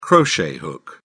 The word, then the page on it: crochet hook: British English